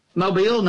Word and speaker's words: Mobile: I'll take you right to Fraser's Mobile? – Bottom. – Mobile, not mobile.